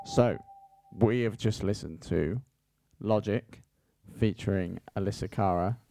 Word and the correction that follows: Alessia Cara: Is that how you say it? – It's Alessia Cara and Khalid.